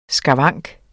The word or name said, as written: skavank